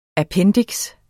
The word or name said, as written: appendiks